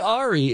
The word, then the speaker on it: Aire: I studied French, so Jacques was what I liked, but they have now changed it to Air Webster. Could be Ari. It's spelled A-I-R-E, but let's go with Air on that.